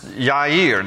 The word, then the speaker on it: Yair: And then number 7 is Yair. There's no J in the Hebrew alphabet, so his name is really spelled with a Y in Hebrew, Yair.